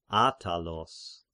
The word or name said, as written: Attalos